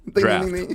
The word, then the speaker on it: draught: D-R-A-U-G-H-T. Draught. – Draft. No, it's draft. – Draft, draft. – Drought is spelled with an O. D-R-O-U. – Draft. – Right, draft.